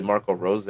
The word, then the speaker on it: Marco Rose: I think the addition of Marco Royce is Rose. I don't know how to say his name. It's not Royce. Yeah, the coach. It's literally Marco Rose or something like that.